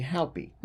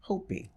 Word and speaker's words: Hopi: So that would be Haupi. – Mm-hmm. – But I've since learned, mainly from watching Manifest and then going back and researching more, it's actually Hopi.